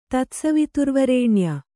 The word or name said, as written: ತತ್ಸವಿತುರ್ವರೇಣ್ಯ